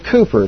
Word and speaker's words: Cowper: But he knew a guy whose name was William Cooper. It's spelled C-O-W-P-E-R. It's not Cowper, it's Cooper.